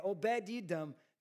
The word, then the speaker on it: Obed-Edom: Yeah, Obed-Edom.